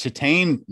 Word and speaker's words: Titane: And I know that it is pronounced Titane now because it is short for titanium.